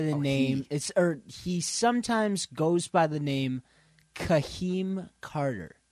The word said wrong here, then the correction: Hakeem: Or, I'm sorry, not Kahim. Oh, my God. I butchered that. Ay, ay, ay. It's Hakeem Carter.